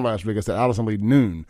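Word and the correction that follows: Nooe: and got corrected by another mutual friend of ours.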